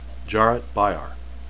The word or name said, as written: jaret